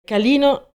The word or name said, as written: Calino